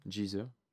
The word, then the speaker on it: Geezer: Tu dirais Geezer Butler ou Geezer Butler? G-E-E-Z-E-R. Parce que moi, je n'ai pas la prononciation. – J. – Hein? – Geezer. – Geezer? – Ouais. – Geezer.